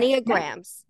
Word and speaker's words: Enneagrams: Enneagrams.